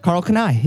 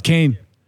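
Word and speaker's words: Karl Kani: That's how you pronounce that? – Carl Kanai, yeah. – I remember Carl Kanai. – Yeah. – I thought it was Kane.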